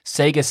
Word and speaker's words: Sega: And also they've added support for the Mayflash Sega Saturn adapter. Sega! That's the proper way to pronounce that for those who didn't know.